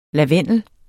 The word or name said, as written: lavendel